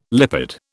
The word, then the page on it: lipid: British English